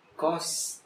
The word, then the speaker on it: costs: Now, pronouncing it is a bitch. You know that the words tasks that you love. I have many tasks to do. They say costs.